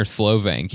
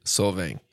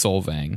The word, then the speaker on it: Solvang: Is it Solvang or Slovang? Can you remember? – Solvang. – It's Solvang? – No, you gotta check.